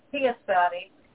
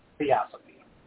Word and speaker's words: theosophy: And that is Theosophy. That was not a mispronunciation of philosophy or theology. It's theosophy.